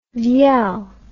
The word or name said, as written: vielle